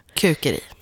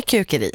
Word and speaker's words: kukeri: För det är så det stavas, ja, med ett U. – Ja. Det kan vi säga. Vi blir uppmanade här på Sveriges Radio att vi ska uttala det mesta svenskt om det går. – Precis. – Så vi säger kukeri.